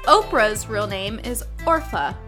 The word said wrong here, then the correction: Orpah: Orpa. Orpa. Orpa. – O R P A H.